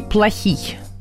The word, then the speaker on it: Плохий: Значит, ударение Сергей Плохий. Это украинская фамилия.